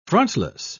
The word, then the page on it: frontless: British English